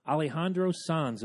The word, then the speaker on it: Alejandro Sanz: Alejandro Sanz, am I saying that right? – Yeah, very good.